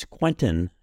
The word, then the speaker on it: Quentin: My name is Quentin Schultze, and Quentin is spelled Q-U-E-N-T-I-N.